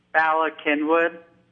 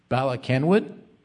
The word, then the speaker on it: Bala Cynwyd: Bala Cynwyd. – Bala Cynwyd? – Yep, that's the name.